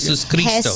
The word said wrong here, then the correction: Jesucristo: You don't pronounce that last S? – No, it's Jesucristo. – Jesucristo.